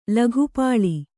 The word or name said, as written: ಲೋಕಪಾಲ